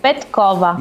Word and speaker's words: Петкова: Можно небольшую поправку? – Давай. – Петкова. – Да, вот Петкова. – Я думал, правильно ли я сделаю ударение на фамилию. Оказалось, что опять я не угадал.